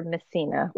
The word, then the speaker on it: Messina: My name is Jennifer Messina, like Meth.